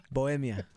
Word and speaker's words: Bohemia: You said it wrong. Bo- Bo- Bohemia.